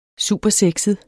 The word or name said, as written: supersexet